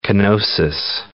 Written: kenosis

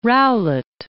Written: Rowlett

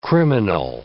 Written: criminal

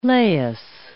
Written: Laius

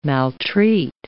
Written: maltreat

